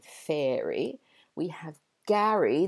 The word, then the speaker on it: Gary: Whereas the boy's name, Gary, G-A-R-Y, that rhymes with Harry, as in Harry Potter, or Prince Harry. And so Mary, the girl's name, has an air vowel sound, and Gary, the boy's name, has an ah sound.